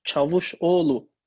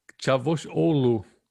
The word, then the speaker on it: Çavuşoğlu: Aber du berätst den, den türkischen Außenminister in deutschen Angelegenheiten, den ähm, Çavuşoğlu, habe ich es richtig gesagt? Richtig, fast. Mevlüt Çavuşoğlu. Çavuşoğlu.